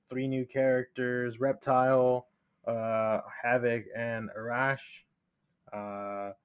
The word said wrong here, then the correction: Ashrah: so, Ashrah, Ashrah, yeah, I believe um kind of hard to pronounce.